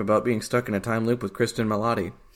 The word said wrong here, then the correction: Kristen Milioti: Is that how you pronounce it? – I'm assuming. Kristen Milioti. I'm sorry, I didn't see the second eye.